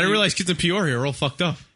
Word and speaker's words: Peoria: Here's a, was it Peoria? Am I pronouncing that right? – Peoria. – Peoria.